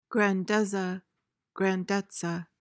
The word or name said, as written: grandezza